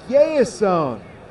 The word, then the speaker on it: Yehizon: Y-O-S-B-E-R. Jasper Sanchez on one side. Jasper hailing from Maracay, Venezuela. And Yeison. Y-E-H-I-Z-O-N out of La Romana, Dominican Republic.